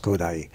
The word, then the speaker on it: Kodály: That's Kodály. But the point is, the accent is on the first syllable.